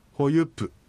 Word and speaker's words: ホユプ: 走るというのはホユプと言います。ホユプというふうに、このプというのははっきり発音します。ホユプというふうに言います。